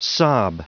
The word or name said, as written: sob